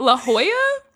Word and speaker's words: La Jolla: For years, I have been saying La Jolla Playhouse, and it is, it's what? La Jolla? – Yeah. – That should not be pronounced like that.